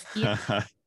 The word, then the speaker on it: Kyiv: By the way, I loved your pronunciation of Kyiv.